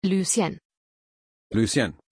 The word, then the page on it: Lucienne: German